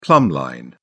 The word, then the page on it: plumb line: British English